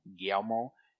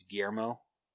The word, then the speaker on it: Guillermo: I used to always say Guillermo. It's Guillermo.